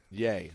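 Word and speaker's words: Ye: Pete Davidson is dating Kim Kardashian, the ex-wife of Kanye West, or Yee. Yay. – Yay, not Yee. You can't say Yee. Got to say yay. – Then he should spell it Y-A-Y.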